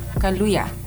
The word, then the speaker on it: Kaluuya: Daniel Kaluuya? – Kaluuya.